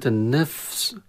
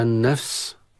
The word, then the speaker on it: النفس: كلمة النفس، كلمة النفس كثير من لا ينطق هذه الكلمة صحيحا، إما أن يحذف الفاء يقرأ النفس، وإما أن ينطق الفاء ضعيفة بجزء من حركتها. الصح أن ننطق الفاء أولا من إذا لصقنا أطراف الثنايا العليا في بطن الشفة السفلى، في وسط الشفة السفلى، في بطن الشفة السفلى، النفس، ثم السين.